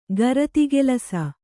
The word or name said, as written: ಗರತಿಗೆಲಸ